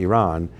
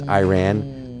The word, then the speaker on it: Iran: And then we talked about the head of the serpent, which is obviously Iran. I know everyone says Iran, but really it's Iran. That's the way they pronounce it, so might as well be respectful at least a little bit.